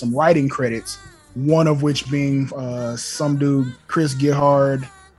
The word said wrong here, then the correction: Gethard: Chris Gethard. Chris Gethard. He was part of the Vice. – Pause, because Jim Cornette read it as Chris Gethard. – Because it's spelled Gethard.